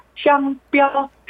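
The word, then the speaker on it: 商标: 상표라는 한자 그대로 쓰고 발음은 둘 다 일성 써서 샹뼈 휘순 쌍뼈가 빨리 나오길 바랍니다.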